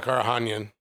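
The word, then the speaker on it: Karakhanyan: So you got AJ McKee versus Georgie, what, how do you pronounce Georgie's last name? Karahanyan? – Yeah, Karahanyan. – Karahanyan, there we go.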